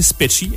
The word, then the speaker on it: Speccy: Je vais le prononcer Specchi. S-P-E-C-C-Y.